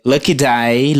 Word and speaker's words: Lucky Daye: é, Lucky Day, Lucky Day, se escreve L-U-C-K-Y, da, daí, né, D-A-Y-E. C-K-Y.